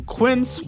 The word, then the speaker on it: Quince: Today Explained's support today comes from Quince, which rhymes with since but is spelt with a Q-U.